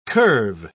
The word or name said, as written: curve